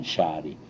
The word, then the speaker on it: Sari: My name, sister's name was S-A-R-I. – S-A-R-I. – Let's pronounce it Hungarian, Shari. But actually it's not S-H-A-R-I.